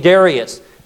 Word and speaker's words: Darius: Where before was Nebuchadnezzar, now we see a man by the name of Darius, or some pronounce his name Darius.